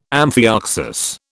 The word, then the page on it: amphioxus: British English